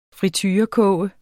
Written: friturekoge

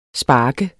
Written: spark

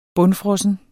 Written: bundfrossen

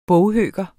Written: boghøker

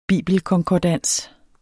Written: bibelkonkordans

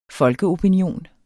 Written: folkeopinion

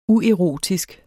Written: uerotisk

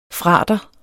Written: frater

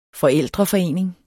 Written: forældreforening

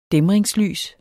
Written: dæmringslys

